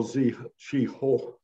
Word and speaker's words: Zheng He: Well, let's start first with that Admiral Zhi Ho, which I believe is the correct pronunciation.